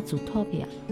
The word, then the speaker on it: Zootopia: 我们的中文翻译叫《疯狂动物城》，它英文翻译叫那个，嗯，英文叫 Zootopia。 – 听，这终于有一个英语发音好的了。